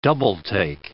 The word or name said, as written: double take